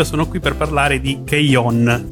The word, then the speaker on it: K-On: Keion, che stavamo dicendo, eh, che si scrive poi K-On, perché molti adesso magari eh, sentendo questo nome non fanno riferimento al, all'anime in sé. È quella serie che si chiama appunto K-On, ma eh, si scrive K-O-N e ha quelle protagoniste molto pucciose, molto carine che eh, suonano, fanno parte di una band scolastica.